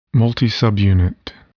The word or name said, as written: multisubunit